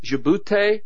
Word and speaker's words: Djibouti: And you can, it's Djibouti is how it's pronounced. Djoubti, I think, something like that.